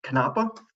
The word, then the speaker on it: Canapa: I pronounce it as Canapa. It is Italian for hemp, and so I'd like to think it's said with a little bit more of a Mediterranean flair than Canapa.